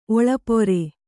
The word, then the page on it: ಒಳ್ಪೇರು: Kannada; oḷpēru